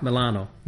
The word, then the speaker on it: Milano: Milan, Italy, organized before by Milan. – Italian accent. – So the proper way of pronouncing it is Milano.